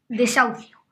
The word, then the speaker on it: desahucio: Está lo de las familias que los bancos echan de sus casas y que he aprendido que se llama desahucio, con una H que está en medio y no se pronuncia.